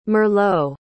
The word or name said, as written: merlot